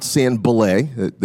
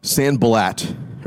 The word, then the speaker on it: Sanballat: Meet a guy named Sanballat. That's not Sanballat. That's very tempting to pronounce it that way. Not a French name, as you would imagine. Sanballat, right? Sanballat. And he's got a crew.